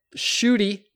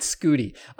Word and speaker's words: Shooty: And also, Shooty, not Scooty. I've Googled Scooty Babbitt so many times that when I try typing in any URL that starts with S, I get Scooty Babbitt. – Shooty Babbitt is already an all-time name.